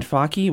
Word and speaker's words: Fakih: Yeah, so he was basically making the life of a restaurateur named Mohamed Faki, which is spelled F-A-K-I-H, in case you're wondering where we're getting the pronunciation.